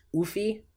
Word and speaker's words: Ouphe: I think it's pronounced Oofy. It's O-U-F or O-U-P-H-E. It's a weird one.